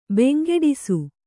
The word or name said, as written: ಬೆಂಗೆಡಿಸು